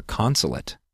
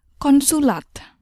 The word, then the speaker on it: konsulat: Finally, we're going to learn the word for consulate. The word is very similar to the English word, so pay attention to the pronunciation. – Consulat. Consulat. Consulat.